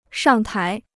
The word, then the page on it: 上台: shàng tái